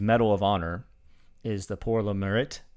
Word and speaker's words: Pour le Mérite: When they get awarded the Pour le Mérite. That's terrible pronunciation. Pour le Mérite. It's actually, ironically, a French name for a German award.